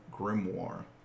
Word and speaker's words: grimoire: And there was a book, which Ed referred to as a grimoire. Satanic. – I'm proud you pronounced that right.